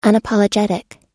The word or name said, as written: unapologetic